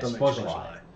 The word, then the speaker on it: Szoboszlai: Szoboszlai, is that how you say it? – Szoboszlai.